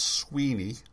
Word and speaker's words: Suibhne: Now, this one is, I believe it's pronounced Sweeney, but it looks like a transliteration of Gaelic, so I could be wrong. Suibhne.